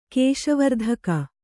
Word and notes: ಕೇಶವರ್ಧಕ: Kannada; kēśa vardhaka

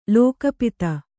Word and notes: ಲಘೂಪದೆ: laghūpade

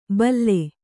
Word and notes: ಭಲ್ಲೆ: kannada

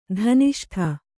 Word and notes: ಧನಿಷ್ಠೆ: dhaniṣṭhe

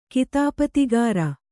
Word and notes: ಕಿತಾಪತಿಗಾರ: kannada

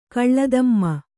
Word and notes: ಕಳ್ಳದಮ್ಮ: kannada

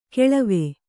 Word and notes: ಕಳವ: kannada

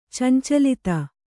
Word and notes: ಚಂಚಲತೆ: cancalate

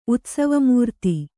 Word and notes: ಉತ್ಸವಮೂರ್ತಿ: utsavmūrti

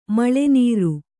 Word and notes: ಮೇಲ್ನೀರು: Kannada

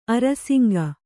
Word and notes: ಅರಸಂಕ: arasaŋka

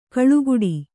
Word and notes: ಕಾಳುಗಟ್ಟು: kāḷugaṭṭu

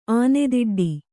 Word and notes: ಆನೇದುಡ್ಡು: ānēduḍḍu

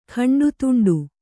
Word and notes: ಖಂಡತುಂಡು: khaṇḍa tuṇḍu

